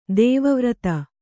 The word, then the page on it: ದೇವವ್ರತ: dēva vrata